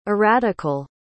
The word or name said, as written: erratical